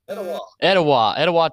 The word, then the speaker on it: Etowah: Etowah, Tennessee, in East Tennessee, where he played high school ball at McMinn Central, lettering in three sports, football, basketball, and baseball.